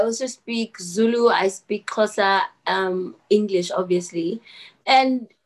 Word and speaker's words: Xhosa: So tell me how to pronounce the X in Shosha or in. – Oh. That was so cute. okay. It's Xhosa.